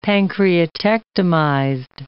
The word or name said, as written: pancreatectomized